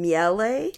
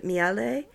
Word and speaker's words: Miele: I thought it was French for honey. Miel. – No, the other E on the end. – Yeah. – I thought that was for aesthetic. – I think they pronounce it Miele.